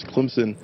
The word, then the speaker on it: Clemson: Some national sports commentators pronounce the C word with a Z. Others with a P in there somewhere. There's a campus divided as well. – It's Clemson all the time.